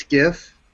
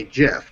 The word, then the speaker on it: GIF: You know, even though I default to GIF, I know better that it's GIF. The dude that invented it said it's GIF, and it's an acronym for graphic interface file or whatever. – Yeah. – So the G in it is a hard G, so GIF. Even though I always, when I'm not thinking about it, I always say GIF, because that's how I said it for so many years.